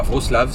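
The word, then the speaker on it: Wrocław: Et on arrive donc à, Wroclaw, Vroslav, ça c'est Wroclaw, mais ça s'écrit Wroclaw.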